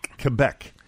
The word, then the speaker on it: Quebec: Is it Quebec or Quebec? – Quebec.